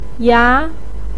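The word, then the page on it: ya: tone 2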